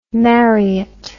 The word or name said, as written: Marriott